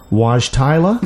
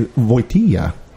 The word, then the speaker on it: Wojtyla: It's Camp Wojtyla. Is that what you... – that was perfectly pronounced.